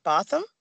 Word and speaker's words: Botham: So when she was convicted of killing, how do you pronounce his first name? Botham.